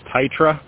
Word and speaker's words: Tytera: I'm actually got my hands on I think it's pronounced Tytra. T-Y-T-E-R-A.